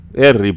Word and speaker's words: Erri: Pero como para sus amiguitos Tano, Harry era raro de pronunciar, quedó Erri, porque le decían Erri, así que ahí tiene ese extraño nombre.